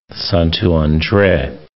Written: Santo Andre

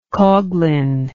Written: coughlin

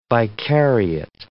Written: vicariate

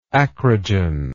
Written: acrogen

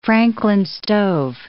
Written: Franklin stove

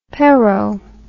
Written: perot

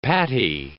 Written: patty

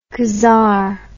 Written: Khazar

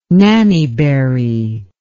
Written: nannyberry

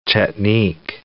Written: Chetnik

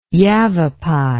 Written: yavapai

